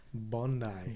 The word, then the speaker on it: Bondi: Bondi.